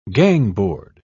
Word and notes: gangboard: British English

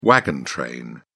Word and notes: wagon train: British English

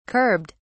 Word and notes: curbed: British English

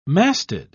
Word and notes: masted: British English

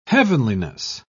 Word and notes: heavenliness: British English

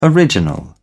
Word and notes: original: British English

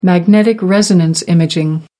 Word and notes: magnetic resonance imaging: American English

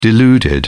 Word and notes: deluded: British English

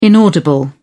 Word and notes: inaudible: British English

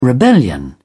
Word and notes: rebellion: British English

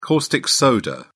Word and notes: caustic soda: British English

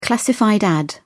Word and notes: classified ad: British English

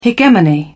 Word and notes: hegemony: British English

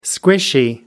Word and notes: squishy: British English

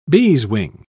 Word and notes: beeswing: British English

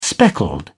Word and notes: speckled: British English

